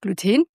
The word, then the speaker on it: Gluten: Also erstens einmal sagt man Gluten oder Gluten, es heißt Gluten. Ich glaube, das Gluten kommt aus dem Englischen. Weil dort wird so ausgesprochen, aber es ist ja ein chemischer Begriff und deswegen heißt es Gluten, falls euch das fragt.